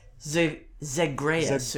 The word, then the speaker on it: Zagreus: Zagreus, okay.